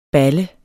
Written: balle

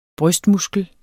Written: brystmuskel

